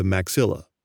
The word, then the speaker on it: maxilla: For simplicity, scientists sometimes just call the upper jaw the maxilla. It rhymes with Godzilla, and it's spelled M-A-X-I-L-L-A.